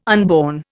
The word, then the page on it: unborn: Indian